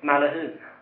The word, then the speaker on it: Maglehem: Hur uttalade de Maglehem förresten? – Malahum. – Det sa de.